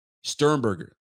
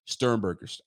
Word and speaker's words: Sternberger: Is this Sternberger? Am I saying it right? Sternberger. That's it. Thank you very much, man. I got it all wrong. Sternberger. I said Stern.